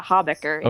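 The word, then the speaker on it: Habecker: Hawbecker. – Okay, yeah. – H-A-B-E-C-K, yeah.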